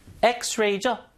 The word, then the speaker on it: X-ray: 자, 철자는 자음 철자지만 읽을 때 어떻게 읽어요? 에, 에, 에, x-ray죠? 어우, 모음 발음이네요, 이게. 그러니까 모음 발음이니까 언이에요. 자, 이게 중고등학교 때 여러분 이걸 철자로 잡고 앞에 언인지 어로 구분하신 분들은 혼란이 올 수도 있어요. 아닙니다. 철자 아니에요. 대부분 모음 철자는 모음으로 읽히지만 이런 경우 좀 예외적으로 X지만 읽을 때 L을 읽으니까 get an X-ray scan 해서 get an X-ray scan 그래서 언이 들어간 겁니다.